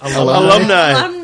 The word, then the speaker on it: alumni: You said Illumini. – What is the word? – Alumni.